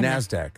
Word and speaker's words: NASDAQ: NASDAQ. – NASDAQ.